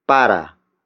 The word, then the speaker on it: para: The next word is para. Para. Para. Para is spelled as P-A-R-A.